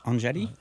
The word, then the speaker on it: Anjali: So we have Anjali.